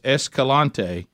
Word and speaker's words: Escalante: Is it Escalante? Is that how you say it? – Oh boy, I think that's it. Yeah, I think that's it. – So Grand Staircase Escalante.